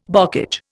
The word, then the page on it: bocage: Indian